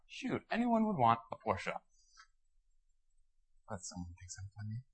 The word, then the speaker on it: Porsche: Okay, and that is the correct way to say it, Porsche.